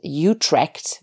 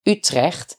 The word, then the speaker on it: Utrecht: Maybe you will pronounce it as Utrecht when you read it, but it's Utrecht.